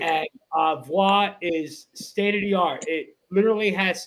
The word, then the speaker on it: Voix: How do you say it? – Voix Lounge. – V-O-I-X. Voix Lounge, 2825 Cotman Avenue.